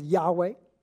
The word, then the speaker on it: Yahweh: Now, this is where we get the title that would later be pronounced as Yahweh.